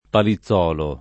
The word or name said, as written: Palizzolo